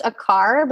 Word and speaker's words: Aquart: So it's just my name, which it's pronounced a car, but if you spell it like a quart of milk, it's Aquart.